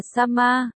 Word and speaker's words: Sama: Sama chính là ý đại nhân trong Nhật ngữ. Đọc là Sama, không hiểu bây giờ bạn đọc đã hiểu chưa?